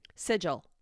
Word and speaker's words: sigil: Sigil. S-I-G-I-L. Is that how you pronounce it? – Yeah.